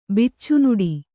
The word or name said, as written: ಬಿಚ್ಚುನುಡಿ